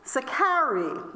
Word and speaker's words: Sicarii: One section of the Zealots were known as Sicarii. Now, how that's spelled is S-I-C-A-R-double-I. S-I-C-A-R-double-I. Sicarii.